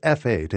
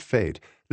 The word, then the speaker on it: F8: Pronounced F8 and not fate,